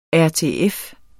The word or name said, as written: RTF